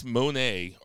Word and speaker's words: Moné: First of all, this Mercedes Moné, or however they say it, they say money and Moné, but they... – No, you said it right.